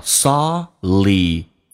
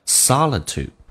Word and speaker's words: solitude: Happiness 这句话就变成 Find your own world Find your own happiness Solitude 这个单词指的是孤独或者独处的意思 Solitude 英式发音会有一些不一样，叫 Solitude 美式发音 T 加 U 一般可以发成 U 这个发音，叫 Solitude 独处 Will make your heart and mind stronger，让你的身心会变得更加的坚强。